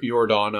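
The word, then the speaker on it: Yordan: Jordan Alvarez. – That Y is pronounced more as a J in the Spanish language.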